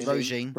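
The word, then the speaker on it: Róisín: it's um, well, I, I found Roshin, I can't even say her name. Roisin. – Roisin, Roisin. I found Yeah.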